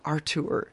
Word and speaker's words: Artour: So there's just the one T in there. So I always hesitate when I'm pronouncing it. You know, don't want to say Studio Art Tour because that would be implied too. Studio Art Tour is how I've decided to pronounce that.